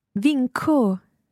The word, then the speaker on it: vinco: So we say, for example, I win. – Vinco. – So that's followed by an O, so it must be a hard C.